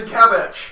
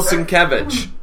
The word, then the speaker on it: Sienkiewicz: Bill Sienkiewicz. – Thank you. Bill Sienkiewicz.